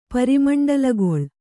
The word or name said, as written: ಪರಿಮಂಡಲಗೊಳ್